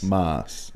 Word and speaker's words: Maas: Yeah, and definitely sounding out does if you were to say my name, which is spelled M-A-A-S, is Moss. – Moss. – And that's how. – And you emphasize the A, like Moss.